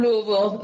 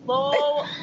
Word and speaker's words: Louisville: So I'm from Louisville, and I was Say it one more time, Louisville. That's how they say it. Say it again. – Louisville.